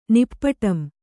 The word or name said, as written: ನಿಪ್ಪುಟಂ